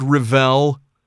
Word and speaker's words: Revell: I've always said Revell. Is that correct? – Yeah. – Okay. – That's the correct pronunciation, yeah.